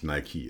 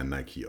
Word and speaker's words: Nike: Die meisten haben ja Adidas an oder Nike. Stimmt, stimmt gar nicht. Ist ja wirklich Nike. Es wird ja Nike ausgesprochen.